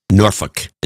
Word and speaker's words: Norfolk: Norfolk is the way it's supposed to be pronounced, to be honest about it.